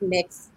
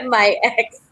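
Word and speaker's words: MYX: It's just letting people know that it's pronounced Mix and not My Ex.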